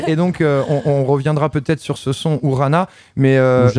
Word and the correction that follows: Ujana: ou Oujana.